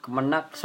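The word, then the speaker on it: Kemenag: Kemenak Kemenak bacanya ya. Iya. Kemenak soal salat terawih hingga sidang isbat.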